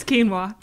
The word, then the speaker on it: quinoa: It's quinoa. – That's what I said. – You said quinoa.